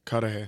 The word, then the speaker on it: Cudahy: She worked as an apartment manager and was from, I think, Coudé, Wisconsin. Am I saying that right? Coudé? – Cudahy. Cudahy, Wisconsin.